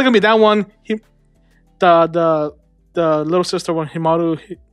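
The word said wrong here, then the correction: Himouto Umaru: Himoto Umaru.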